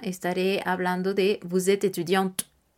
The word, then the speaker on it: étudiante: Pero la pronunciación será étudiante.